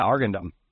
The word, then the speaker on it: Arghandab: Is it Argandab? Argandab? – You had it right, yeah, Argandab.